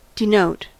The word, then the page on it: denote: American English